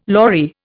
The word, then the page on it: lorry: Indian